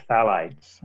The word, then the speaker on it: phthalates: Yeah, so it's the third class of chemicals would be the phthalates, and that's spelled P-H-T-H-A-L-A-T-E-S.